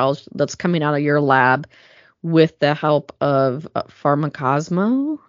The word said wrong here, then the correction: Pharmacosmos: Yeah, Pharmacosmos.